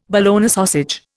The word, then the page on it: Bologna sausage: Indian